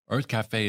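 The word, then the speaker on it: Urth Cafe: Earth Cafe is my jam. – It's Earth Cafe. – Earth. It's spelled, for you listeners who might one day visit West Hollywood, California, it's spelled U-R-T-H.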